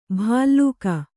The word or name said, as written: ಭಲ್ಲೂಕ